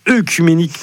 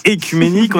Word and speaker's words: œcuménique: Et nous sommes le 8 octobre et c'est donc l'anniversaire de l'ouverture du concile écuménique de Chalcédoine, le 8 octobre 451. Alors, concile écuménique, ça peut sembler une expression un peu barbare. Déjà, précisons qu'on ne dit pas œcuménique, mais écuménique.